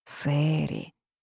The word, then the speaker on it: Сэри: Сэри. Сэри. Любовь слепа. Поэтому в глазах Сэри даже несуразность усика выглядела привлекательно. Пишется через «э». Она захотела подчеркнуть это, чтобы показать, насколько современное у нее имя.